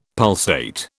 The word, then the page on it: pulsate: British English